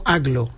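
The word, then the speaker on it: Agloe: No, entiendo que Aglo, yo lo he escuchado pronunciado como Aglo.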